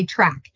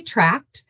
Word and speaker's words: tract: say track, but it's actually tracked. So if you hear me say track, I'm saying it wrong.